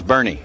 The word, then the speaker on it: Boerne: I mean, that was a Born, B-O-E-R-N-E, Born. – It was Bernie, yeah. – Bernie, yeah. – Correct. – No, that's right. They pronounced that horse, that's Bernie.